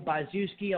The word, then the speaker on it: Byzewski: If you are on social media, Facebook, my name is Daniel Byzewski.